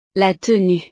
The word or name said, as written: la tenue